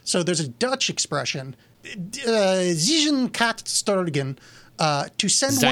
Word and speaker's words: zijn: The IJ in Dutch is pronounced I. So, Zijn is Zijn.